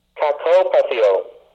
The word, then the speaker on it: kakopatheo: Strong's Concordance 2553, from the Greek word kakopatheo, which is spelled K-A-K-O-P-A-T-H-E-O, meaning to suffer evil.